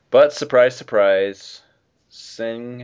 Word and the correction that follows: Sin Fang: is that his name? Sing Fang. Am I saying that right? Yeah. – Sin Fang. There's Sin Fang. – no G in the first.